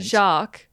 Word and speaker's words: Jacques: It's Jacques. The S is silent. – Jacques.